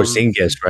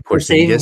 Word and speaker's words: Porzingis: Um, you know, there's been a lot of talks about, you know, trading with um, the Mavericks for I can't pronounce that dude's name, Pazonic. Um, Porzingis, right? – Porzingis. Porzingis. There we go.